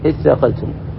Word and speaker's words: اثاقلتم: فأتي بالهمزة، همزة الوصل، علشان تستطيع أن تنطق بها، فتقول إثاقلتم، وإلا الأصل تثاقلتم من التثاقل، وهو التكاسل والتعاجز والتصاعب.